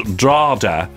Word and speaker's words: Drogheda: Drogheda, locally pronounced Drada.